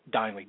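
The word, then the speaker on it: Dineley: It seemed like that the defense let Agnes Dinely hung out to dry, and that's how his name is pronounced, Dinely, D-I-N-E-L-E-Y. I was saying Dinley for the longest time. It's Dinely. I checked that out with my good friend Jake Elliott, who does the play-by-play for the Minnesota Swarm.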